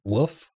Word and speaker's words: wolf: I should call out your subtle accent where you don't really pronounce the L in wolf. And you say woof.